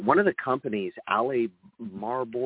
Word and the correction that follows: Albemarle: or hold on a second. I got to pronounce the name of it. Albemarle is the biggest lithium miner in the world.